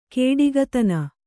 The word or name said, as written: ಕೊಡುಗೈತನ